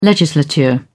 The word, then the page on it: legislature: British English